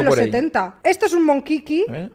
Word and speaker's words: Monchichi: Se llaman los Monkiki, se escribe Monchichi, ¿te suena? – Monkiki era mi profesor de geografía.